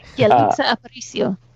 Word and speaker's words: Yalitza Aparicio: And I want to give definitely big praise to, I'm going to butcher her name. – Yalitza Aparicio. – Yeah. Okay. So I wasn't going to actually, Yalitza Aparicio, who played Cleo, who was also nominated for Academy Award for Best Actress, is so good in this movie.